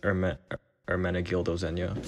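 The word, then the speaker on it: Ermenegildo Zegna: Ermenegildo Zenya.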